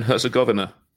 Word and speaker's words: Herzegovina: Herzegovina, I think you pronounce it. – How do you do it? – Bosnia and Herzegovina. – Yeah, Herzegovina.